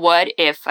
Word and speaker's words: what if: De novo, o what if aqui, né? E repara na pronúncia. What if? De novo, o T soa como um R e fica what if.